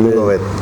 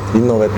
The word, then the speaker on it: Innovet: ¿Cómo se pronuncia, vos que sos profesor, cómo se pronuncia la T ahí al final? – Innovet. – ¿Cómo? – Innovet. – ¿Y yo cómo dije? – Y está, dijiste bien.